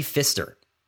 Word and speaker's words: Pfister: Inception, Wally Pfister.